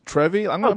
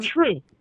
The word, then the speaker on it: TRVE: The TRVE Brewing Company, Trevi? – True. – Is it true? Is that how you say it? – It's true, yeah. – I was going to call it Trevi. I got a true, which is spelled TRVE, whatever.